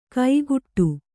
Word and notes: ಕೈಗುಟ್ಟು: kaiguṭṭu

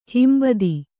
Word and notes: ಹಿಂಬೊತ್ತು: himbottu

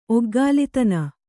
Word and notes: ಒಗ್ಗಾಲಿತನ: Kannada

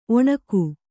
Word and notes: ಒಣಿಗೆ: kannada

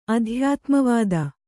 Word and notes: ಅಧ್ಯಾತ್ಮವಾದ: adhyātmavāda

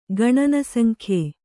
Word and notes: ಗಣನಸಂಖ್ಯೆ: gaṇana saŋkhye

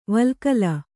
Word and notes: ವಲ್ಕಲ: valkala

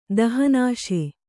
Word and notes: ದಹನಾಶೆ: Kannada

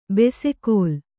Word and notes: ಬೆಸೆಕೋಲ್: bese kōl

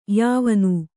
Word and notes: ಯವನ: yavana

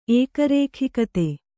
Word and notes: ಏಕರೇಖಿಕತೆ: Kannada

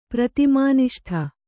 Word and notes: ಪ್ರತಿಮಾನಿಷ್ಠ: pratimāniṣṭha

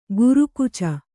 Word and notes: ಗುರುಕುಚ: guru kuca

